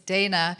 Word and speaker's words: Dana: Hi, my name is Dana Gavin. I guess Dana in America, just to make sure it's spelled correctly.